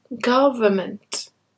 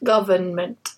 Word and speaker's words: government: Normally in English when we have the suffix ment, there's an N before it. And that N is silent. So it's environment, government, not environment and not government.